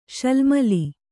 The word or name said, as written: ಶಾಲ್ಮಲಿ